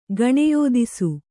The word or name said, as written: ಗಣೆಯೋದಿಸು